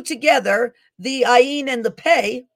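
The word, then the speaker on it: Pe: Pei, P-E-Y, is the 17th letter of the Hebrew alphabet, okay? Well, it's actually P-E-I is actually how it's spelled. Sometimes it means it's P-E-H, but it's Pei, okay, or Pei. This means mouth, right?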